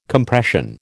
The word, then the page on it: Compression: American English